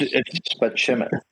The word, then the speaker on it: Spaceman: It's pronounced Spicelli.